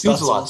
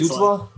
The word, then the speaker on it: Stützle: You, like, I feel like you said it wrong and you never say players' names wrong. And then I was like, wait, hold on. I'm now lost. Yeah. Stützle. There we go.